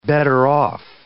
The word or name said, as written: better-off